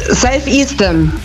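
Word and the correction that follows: Self Esteem: Ja, Self-Esteem nehmen wir auch.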